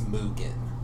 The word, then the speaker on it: Mugen: And my name's Mugen.